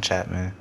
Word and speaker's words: Chatmon: Antoine Malone Chapman. A-N-T-W-O-N. And the last name is C-H-A-T-M-O-N.